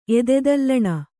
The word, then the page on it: ಎದೆದಲ್ಲಣ: Kannada